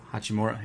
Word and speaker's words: Hachimura: It's a lottery pick, but and I'm not even – I can't believe I'm not sure how to pronounce this, but Ryu Hachimura. – Hachimura, yeah.